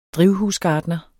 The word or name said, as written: drivhusgartner